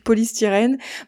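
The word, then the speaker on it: Poly Styrene: Je vais le prononcer à la française, comme le plastique polystyrène.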